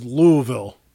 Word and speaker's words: Louisville: There's no, I thought it was Louis or Louis. There's no second syllable to that vowel. It's just Louisville.